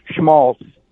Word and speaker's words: schmaltz: Well, there's a T in there, but I'm thinking that the way I have always heard it pronounced was without the T. Schmaltz.